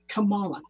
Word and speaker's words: Kamala: And in Sanskrit, the lotus is said like Kamala, K-A-M-A-L-A.